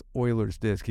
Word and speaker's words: Euler's Disc: It's called Euler's Disc, although a lot of people call it Euler's Disc. It's spelled E-U-L-E-R, but it's pronounced Euler's Disc.